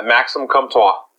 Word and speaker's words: Comtois: Maxim Comtois.